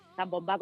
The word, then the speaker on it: zambomba: Lo único que zambomba con acento en la A,